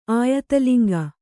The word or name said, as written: ಆಯತಲಿಂಗ